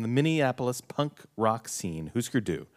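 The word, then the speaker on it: Hüsker Dü: Husker Du. – Husker Du. – If you pronounced all the umlauts, you would say Husker Du.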